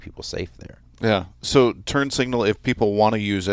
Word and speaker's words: TurnSignl: You can download its TurnSignl spelled without the A, so T U R N S I G N L.